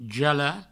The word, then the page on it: jalar: jala; Provençal